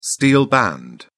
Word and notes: steel band: British English

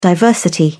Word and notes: diversity: British English

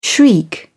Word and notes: shriek: British English

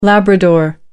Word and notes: labrador: American English

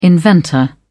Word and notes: inventor: British English

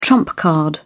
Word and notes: trump card: British English

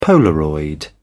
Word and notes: polaroid: British English

